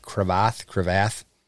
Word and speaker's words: Cravath: and Hillside Elementary or Cemetery adjacent to Cravath Lake. How do you say that? Cravath? – I think that's right.